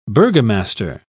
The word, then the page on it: burgomaster: British English